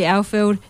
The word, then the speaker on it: Alfeld: Loving your accent, so just for our listeners out there, we've got Lily Alfeld. How do you say your surname, Lily? – Alfeld. – Okay. Alfeld. Yeah, one of a handful of New Zealand internationals we've got in the team here and across the league too.